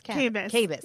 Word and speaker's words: Kabas: Did I say it's Kabas.